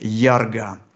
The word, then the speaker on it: Ярга: Давайте попробуем со следующей, вот тут уже действительно агмой, следующий образ 17-й, который называется, произносится как Ярга.